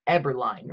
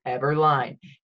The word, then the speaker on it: Eberlein: Today we interview Mr. Marc Eberlein, right? Eberlein, how do you say it? – It's Eberlein, you're correct. – Eberlein.